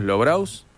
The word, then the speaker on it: Lobraus: Lobraus. Se debe pronunciar así, ¿no? Como sí. – Se escribe. ¿La pronunciamos Lobraus? – Sí, ¿eh? Yo la pronuncio así.